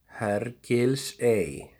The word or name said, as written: Hergilsey